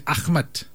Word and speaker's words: Ahmad: Because in Malay, they don't say Ahmed, they say Ahmad.